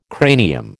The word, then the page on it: cranium: American English